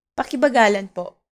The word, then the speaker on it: pakibagalan po: In Filipino, slowly please is pakibagalan po. Pakibagalan po. Let's break it down by syllable. Pa-ki-ba-ga-lan po.